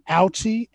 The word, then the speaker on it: Aliche: I think her last name is pronounced Alchi, A-L-I-C-H-E.